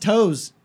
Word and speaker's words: Taos: It's pronounced toes.